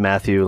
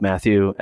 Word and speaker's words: Mathieu: So my name is pronounced Matthew, like the, you know, the apostle.